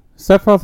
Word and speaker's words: Sephiroth: To be honest, Sephiroth has always been a quote-unquote good guy. – Just because you were a bad guy doesn't mean... Wait. Oh, yeah, I was saying it right.